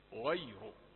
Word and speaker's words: غير: انظر إلى كلمة غير كيف انتبهنا ورققنا الياء حتى لا تتفخم أو لا تأخذ شيئا من التفخيم من الغين أو الراء، حرفان مفخمان أحاط بها، فتقول غير، نقول يحسب عليك أنك فخمت الياء، والياء عمرها ما كانت حرف تفخيم. غير غير س يعني تتعجب في البداية من هذا النطق، لكن ستعلم عندما تسمع تستمع إلى القراء أن هذا هو النطق الصحيح غير.